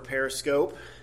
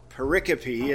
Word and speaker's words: pericope: This is not pericope or periscope. It's pericope is how it's pronounced.